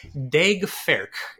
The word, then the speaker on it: Daeg Faerch: His name is Daeg Faerch, uh, D A E G F A E R C H, which I googled three times to make sure I was pronouncing right.